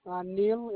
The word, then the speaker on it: Neil: Neil, N-E-I-L. Last name is M-O-T-E.